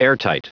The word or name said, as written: airtight